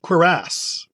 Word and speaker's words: cuirass: Okay, this word is pronounced cuirass. Cuirass.